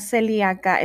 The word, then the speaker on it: celíaca: Esta palabra celíaca es una palabra estrújula, se acentúa en la antepenúltima sílaba que viene siendo la vocal I.